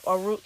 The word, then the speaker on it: O'Rourke: So another person that checked in is Beto O'Rourke. – Mm-hmm. – Pronouncing it right. Yep, O'Rourke.